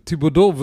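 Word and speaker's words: Thibodeaux: Aber das spricht man doch nicht so aus. Das ist doch ein französischer Name, oder? Thibodeau, äh, Thibodeau wird er eigentlich auf Französisch.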